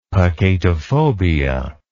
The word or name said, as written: peccatophobia